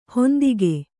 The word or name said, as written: ಹಿಂದೆಗೆ